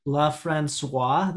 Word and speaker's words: LaFrancois: And then also LaFrancois, that's how you pronounce it? – Yes.